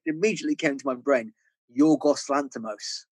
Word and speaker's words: Yorgos Lanthimos: Like, is that how you pronounce it? Yeah, yeah, yeah. yeah. I up? – As a Greek man, Yorgos Lanthimos is is is perfect pronunciation.